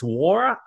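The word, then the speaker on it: Nwora: It's Awara. That's the pronunciation I have on this, out of Louisville.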